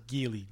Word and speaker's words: Geely: Geely or Geely? Geely? – Geely? – Like the Jennifer Lopez film? – Geely? – Geely? – This is Geely. – Geely. – G-E-E-L-Y.